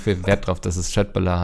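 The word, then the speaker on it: köttbullar: ähm, hat sich beschwert darüber, dass ich äh, Köttbula beim letzten Mal falsch ausgesprochen habe, denn es heißt Schöttbula.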